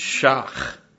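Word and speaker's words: schach: But the roof or the it's tough to pronounce, because it's kind of like you're just clearing your throat. It's shach. I believe that's the way I heard it. Was that close to right? Yeah, okay.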